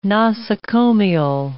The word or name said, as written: nosocomial